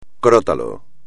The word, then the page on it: crótalo: espagnol